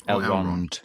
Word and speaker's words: Elrond: Elrond.